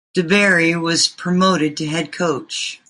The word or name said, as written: promoted